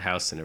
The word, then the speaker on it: Houston: And I believe it was Houston Street, which is spelled just like Houston, but they pronounce it Houston over there.